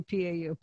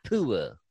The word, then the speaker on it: PUA: And then you got stimulus checks and PUA, I always say it wrong, PUA, I always say PAU, PUA, Pandemic Unemployment Assistance Checks. – PUA, it's PUA. – Now last month we had the highest monthly deficit ever. – It's PUA.